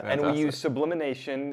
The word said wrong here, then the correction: sublimation: Did I say that rightly? – Sublimation. – Sublimation.